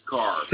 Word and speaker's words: Carr: Carl. – Spell it. Bronson, well, I get it from Anna. – It's Carr. – Carr. Okay, I got you. – C-A-R-R.